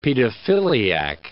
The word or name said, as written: pedophiliac